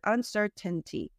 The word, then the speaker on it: uncertainty: If you just read it or when you're just doing one word, uncertainty. But the way Americans pronounce it, we have that uncertain, the glottal stop. So it sounds like uncertain.